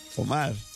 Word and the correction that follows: Amar: Amar, no Omar.